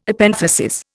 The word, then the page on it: Epenthesis: Indian